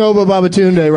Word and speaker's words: Oba Babatunde: I know I'm pronouncing Oba Babatunde right, because I love that name.